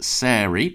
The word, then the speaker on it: Sairy: And the reason I bring up Brighton in particular is because I had a very nice email from a Brighton band called Sairy, pronounced like fairy.